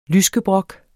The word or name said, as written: lyskebrok